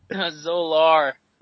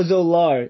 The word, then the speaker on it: Zolar: So you take the the, the Zoloff. Is that what how you say it? – Zolar. – Oh, Zolar.